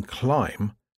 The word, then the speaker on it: climb: For example, the B in climb isn't pronounced.